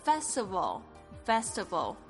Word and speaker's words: festival: 这个 Spring S-P-R-I-N-G 就是春天的意思， Spring。然后呢这个 festival 记得这个 V 的发音呢是轻轻的点住这个前排牙齿，轻轻的咬的这个下面的嘴唇，然后发出这个 V 的声音， festival 这个是节庆的意思，合在一起就是在讲我们的这个中国的春节。